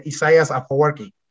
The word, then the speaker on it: Isaias Afwerki: Isaias Afwerki. Yeah, am I pronouncing his name incorrectly? – No, you're pronouncing it correctly. Isaias Afwerki.